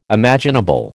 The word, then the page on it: imaginable: American English